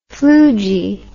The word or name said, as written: floogy